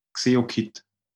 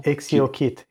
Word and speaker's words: XeoKit: So it's not, it's a mixed, it's XeoKit viewer. – Yeah. How did you say it was called? – XeoKit. X-E-O. – XeoKit.